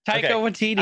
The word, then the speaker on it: Taika Waititi: But we see this shirt on a gentleman that is very similar to what Taika Waititi. I think I said it right, finally. – I think you did. – Did I do it? – Taika Waititi.